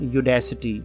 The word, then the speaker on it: Udacity: There is one more name that is called Udacity. Means I pronounce it that way. U-D-A-C-I-T-Y.